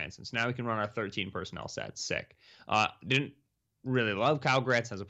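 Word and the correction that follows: Kylen Granson: Kylan Granson. I keep saying his name wrong. Kylan Granson. Didn't think Sean Davis was draftable out of Florida.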